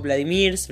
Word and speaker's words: Vladimir: su nombre se escribe con B corta. Yo soy Vladimir con B larga.